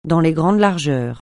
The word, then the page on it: dans les grandes largeurs: francais